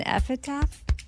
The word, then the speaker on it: epitaph: An epitaph? Epitaph? Oh. Epitaph. – So it's like when someone dies. That's what that is. – Is that how you say it? – Yeah, sure.